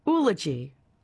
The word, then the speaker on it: oology: found a pronunciation of oology and... – Oology. – Oology. – Oology. – There you go.